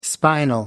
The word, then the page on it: spinal: American English